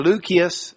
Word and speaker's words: Lucius: I think, I don't know who Simeon, who was called Niger, or Lucius, is the way you'd say it, hard C's, Lucius of Cyrene.